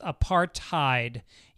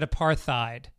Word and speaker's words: apartheid: Dean said, "apartheid is pronounced apartheid. You said it more like how Mike Tyson would say it." I did. I said apartheid.